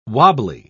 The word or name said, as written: wobbly